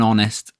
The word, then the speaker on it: honest: Even though honest, the adjective that describes the noun, starts with a H, we don't pronounce it. We say an honest man. When we pronounce it, we're not saying the H. You could get rid of the H. Honest. O-N-E-S-T. Honest. The H is silent here.